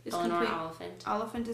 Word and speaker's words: Eleanor Oliphant: Eleanor, oh, how do you say her name? Eleanor Ophelient. – Eleanor Oliphant is completely fine.